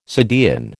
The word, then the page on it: subdean: American English